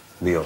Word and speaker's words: vio: Био. С ударением.